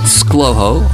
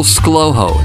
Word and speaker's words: ScLoHo: You can find me online at scotthoward.me, on Twitter at ScLoHo, on Facebook, LinkedIn, Instagram, and a few other places online. Just Google ScLoHo, that's spelled S-C-L-O-H-O, and you'll probably find more of me.